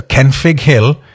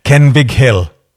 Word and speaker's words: Kenfig: Can you just tell people that are thinking of coming on the radio that one F is pronounced like a V? So Kenfig Hill is not a soft fruit. Kenfig Hill.